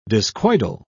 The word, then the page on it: discoidal: British English